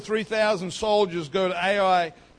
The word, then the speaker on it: Ai: A little while later, after the armies rested and refreshed, the next town that they decide to conquer is a little place called Ai. And that's how it's spelled, A-I. Ai.